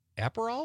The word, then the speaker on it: Aperol: Liz from Sun Prairie says she's going to have tequila and soda with a lime or a splash of Aperol.